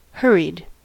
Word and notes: hurried: American English

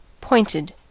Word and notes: pointed: American English

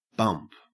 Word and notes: bump: Australian English

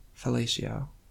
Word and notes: fellatio: American English

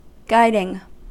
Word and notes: guiding: American English

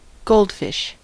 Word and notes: goldfish: American English

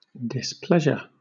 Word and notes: displeasure: British English

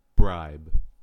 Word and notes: bribe: American English

